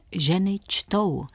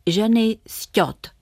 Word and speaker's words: Ženy čtou: Chytře si pomohla anglickým spojením which toe, což znamená který palec. Přestože tento obrat v rodné řeči vyslovila Elzí bezpečně správně, v češtině vždy přečetla ženy sťot.